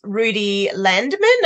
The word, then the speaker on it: Rudi Landeman: Our guest speaker for this morning is Rudi Landeman, and I hope I've said that correctly.